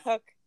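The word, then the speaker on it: Cooke: Cook. I think it's pronounced Cook.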